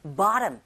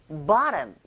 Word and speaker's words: bottom: 그렇게 들릴 텐데 북미권에서는 칫사운드 강몸 양몸 사이에 자리 잡고 있을 때 플랩 현상에 의해서 bottom 이렇게 소리 난다라는 거. 여기 강세가요 O라는 철자 위에 있습니다. 그래서 보톰이 아니라 바아에 가깝게 bottom 이렇게 발음하시면 돼요.